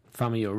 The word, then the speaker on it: Famuyiwa: Famuyiwa? – Yes. I'm going to just. – Famuyiwa. Rick Famuyiwa. – Good one. – There you go. You say it correctly.